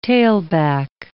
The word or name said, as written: tailback